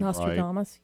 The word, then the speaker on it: Nostradamus: You recall the writings of Nostradamus. Did I pronounce that name Nostradamus? – Right? – Yeah, that's good.